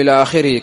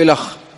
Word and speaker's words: إلخ: قال وقولي مسبوقة إلى آخره، قول إلى آخره كما مر معنا تكتب هكذا ولا تلفظ هكذا ولا تنطق هكذا، فلا تقول إلخ كما لا تقول في انتهى المكتوبة بالألف والهاء كما لا تقول أه، بل تقول انتهى.